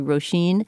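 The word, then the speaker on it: Róisín: Okay, well, she was one musician that we talked about whose name we apparently mispronounced because it's one of those, you know, Irish names that's spelled quite differently from how it's pronounced. It's actually Roisin.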